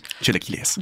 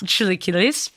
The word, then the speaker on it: chilaquiles: Chilaquiles. – Chilaquiles. When we lived in Chicago, every weekend we went to breakfast at a little hole-in-the-wall place up in the burbs, and I always ordered the chilaquiles.